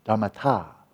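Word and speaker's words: dhammatā: The phenomenon-ness, dhammata. Because that ta, T-A with an akron, a long A, dhammata, is just, what is it about these phenomena that makes them phenomena, right?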